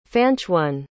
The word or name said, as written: fanchone